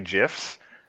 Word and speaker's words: GIF: They say Apple Silicon, which nobody says, but they can't say GIFs. So upset. – I am firmly in the GIF camp because it is a graphical... The word the G is graphical. It's a hard G.